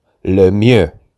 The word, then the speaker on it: Lemieux: His name is pronounced Mario Lemieux.